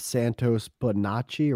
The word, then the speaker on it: Bonacci: We're going to have Santos Bonacci, or is that how you pronounce his name? – Yeah, Dan? – Yeah.